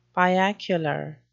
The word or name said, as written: piacular